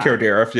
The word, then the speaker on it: Kilgariff: But basically, Karen Kilgariff, did I say it correct? You say it right every time. – Kilgariff, yes. Amazing.